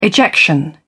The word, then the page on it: ejection: British English